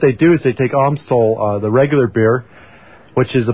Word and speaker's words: Amstel: That's Amstel.